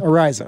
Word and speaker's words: Araiza: And it sounded like, from what we gather, how do you say this guy's name? Is it Matt Araiza? Araiza? – Araiza. – Araiza. – Correct.